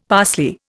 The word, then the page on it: parsley: Indian